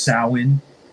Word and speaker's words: Samhain: If you want to be technical, it's pronounced Samhain. Okay, but no one, pagan. – Pagan and Gaelic for Halloween. But nobody says that. Everybody just says Samhain.